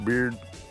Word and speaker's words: Baird: Baird is spelled B-A-I-R-D. I think I said that. I think it's kind of pronounced Beard.